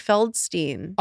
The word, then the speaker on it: Feldstein: It's Beanie Feldstein.